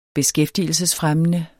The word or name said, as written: beskæftigelsesfremmende